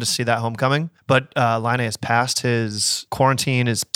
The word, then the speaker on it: Laine: I called him Lane, and then I've been hearing Laine. So Laine. That's yeah. That doesn't look right.